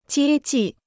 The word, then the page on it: 接济: jiē jì